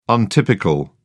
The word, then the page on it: untypical: British English